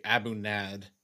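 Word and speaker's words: A'bunadh: Was I supposed to just get the Abuna, which is spelled, by the way, Abunad with an H at the end? – Correct. The D is silent. It's pronounced Abuna.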